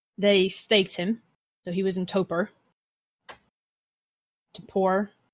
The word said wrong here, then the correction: torpor: How's that word pronounced? I'm sorry. – Torpor. But the way you said it would have been like super like, I'm struggling with the English because I'm Welsh.